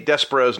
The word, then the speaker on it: Despero: pointing out that in Who's Who, which is a comic I have a little bit of familiarity with, there's actually a pronunciation glossary that specifically tells you how to say Despero's name. So yes, it's Despero.